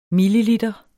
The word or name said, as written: milliliter